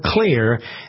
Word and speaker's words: Xlear: It's called Clear, and it's spelled X-L-E-A-R.